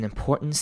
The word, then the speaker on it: important: Note-taking is an important skill. Important 呢，这个字之前也有提过，这边再复习一次，后面的 T-A-N-T 我们会念成嗯的声音，然后中间稍微留个空格，所以会念成 important.